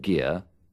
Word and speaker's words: Gere: Por ejemplo, he aquí tres formas diferentes de pronunciar la G. – Kim Basinger, Richard Gere, Jessica Lange.